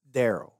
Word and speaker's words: Daryl: It's pronounced Daryl. – Daryl. – D-A-R.